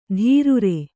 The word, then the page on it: ಧೀರುರೆ: kannada